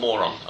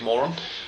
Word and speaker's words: Morón: so I should clarify first of all that it's pronounced Moron, not Moron, unfortunately. My ex-girlfriend, who was the reason I first came to Argentina, went to the University of Moron, And I have a Deportivo Morón shirt back at home in England.